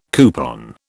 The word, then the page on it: coupon: British English